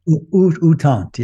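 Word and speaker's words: U Thant: Just looking at the picture in the slideshow of that guy, how do you say his name again? Uthant? – Uthant, yeah.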